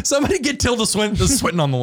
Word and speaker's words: Tilda Swinton: Tilda Swinton.